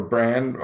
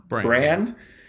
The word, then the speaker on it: Bran: Have Bronn, by the way, or Bran, I always mispronounce it, sorry. – Bran